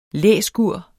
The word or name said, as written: læskur